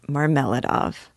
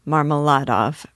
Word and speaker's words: Marmeladov: Second, it was brought to my attention that in the first two chapters, I mispronounced Marmeladov. I said it Marmeladov, because I had found a resource that pronounced it that way, and since then switched to one in which I place greater trust. So, I will be pronouncing it Marmeladov from here out, and eventually I'll probably go back and edit the first two chapters, because I'm a bit of a perfectionist.